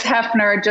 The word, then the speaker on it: Hefner: It's Hefner, just like Hugh.